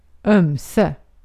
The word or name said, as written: ömse